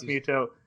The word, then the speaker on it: Mytho: It's pronounced Mytho.